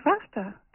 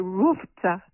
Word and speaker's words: RFTA: It's the Roaring Fork Transportation Authority called RAFTA. It's spelled RFTA. The A is added when you say it, because without it, you'd have to say RUFTA, which isn't real zippy.